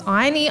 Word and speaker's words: Ione: My name is Ione, I-O-N-E. It rhymes with tiny, which is fortuitous as I am 5'2", and it was my nickname in high school, Tiny Ione.